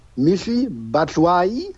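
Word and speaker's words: Michy Batshuayi: Laut der Aussprache, Auskunft von transfermarkt.de heißt der Junge Michi Batshuayi und kommt vom FC Chelsea, leihweise für ein halbes Jahr.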